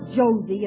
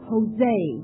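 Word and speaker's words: José: It's not Josie, it's Jose. In Spanish, J is pronounced like H.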